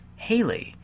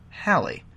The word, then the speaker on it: Halley: but you mispronounce his name as Haley, as in the 1950s rocker Bill Haley, rather than rhyming with Valley, as in Halley.